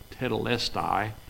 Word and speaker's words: tetelestai: That is it's been pronounced several different ways. I pronounce it tetelestai,